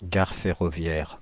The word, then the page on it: gare ferroviaire: France (Île-de-France); /ɡaʁ fɛ.ʁɔ.vjɛʁ/